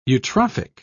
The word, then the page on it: eutrophic: British English